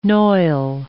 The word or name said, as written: noil